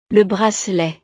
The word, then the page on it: le bracelet: frances